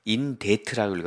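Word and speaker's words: debt: 근데 여기서 전 설명해 놓은 단어가 owing money는 돈을 빚진 이런 뜻이고 in debt라고 읽어야 돼요. 이걸 in debt라고 읽으면 안 돼.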